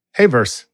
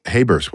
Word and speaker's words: Hayverse: And says, Heyverse, spell that. – HAYVERSE. Heyverse. – Heyverse. Wow. Okay. – Yeah. Like universe, but just Heyverse.